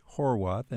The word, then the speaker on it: Horwath: Our guest is the author, Rich Horwath, and if you're looking it up, it's spelled H-O-R-W-A-T-H.